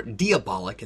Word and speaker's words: Diabolik: But music is actually where I'd like to start with the 1968 Italian film Danger Diabolik, or Diabolik as it's pronounced in Italy, because I've never seen a comic book film like this that feels almost entirely designed around a soundtrack.